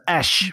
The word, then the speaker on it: Æ: Ash De, de letter I, dus de A en de E in elkaar, wordt uitgesproken als Ash.